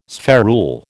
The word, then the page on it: spherule: American English